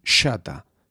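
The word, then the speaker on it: ŝata: Cuidado con la pronunciación. No hay que confundir sata, estar satisfecho o satisfecha, con shata.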